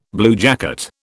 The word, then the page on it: bluejacket: British English